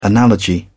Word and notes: analogy: British English

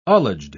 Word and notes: ullaged: British English

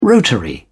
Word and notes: rotary: British English

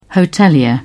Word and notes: hotelier: British English

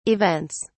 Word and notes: events: British English

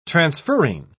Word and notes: transferrin: British English

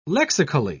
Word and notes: lexically: British English

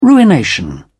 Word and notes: ruination: British English

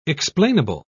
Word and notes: explainable: British English

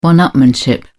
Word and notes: one-upmanship: British English

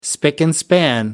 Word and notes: spick and span: British English

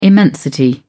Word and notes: immensity: British English

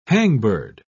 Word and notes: hangbird: British English

